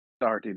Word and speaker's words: Doherty: Well, here's the mad thing about how English people pronounce it, is that when you spell it in Irish, there is a CH. That's the mindfuck. But over here, it's just Doherty. You say it as you see it. There's no hidden C.